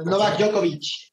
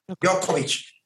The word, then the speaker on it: Djokovic: Dobochik, Nomochik. Ah, Novak Djokovic. Djokovic.